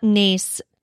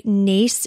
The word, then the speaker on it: Naas: And Naas, I believe, is the, and I'm probably going to get this backwards because I looked it up, and I think Naas is the Irish way to pronounce it, but Naas is like the non-Irish way to pronounce it.